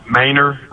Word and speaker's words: Menard: Menard, Texas. Where is Menard, Texas? – Sorry, it must have been mispronounced. Maynard, right outside of Austin.